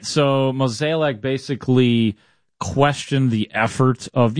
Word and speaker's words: Mozeliak: Mozeliak, how do you say it? – Mozeliak, I do believe. – Yeah. Whatever it is. Yeah, it doesn't make a difference. – Mozeliak. Just say it like Harry, and you're always good.